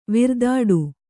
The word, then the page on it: ವಿರ್ದಾಡು: kannada